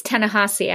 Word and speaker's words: Ta-Nehisi: I think it's Ta-Nehisi, actually.